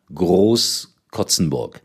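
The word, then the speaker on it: Großkrotzenburg: Der Verein heißt richtig Germania Groß Kotzenburg. Nach 0 zu 5, 7 zu 5 gewonnen in der Landesliga in Hessen. Also Germania Groß Kotzenburg. Ich habe das gestern irgendwie falsch ausgesprochen.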